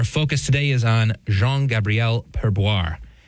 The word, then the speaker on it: Perboyre: I don't really blame you for taking a double take at the name, Sarah, because it's spelled P-E-R-B-O-Y-R-E. Normally, I would think that Y would be an I, but there's a Y there.